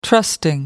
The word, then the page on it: trusting: American English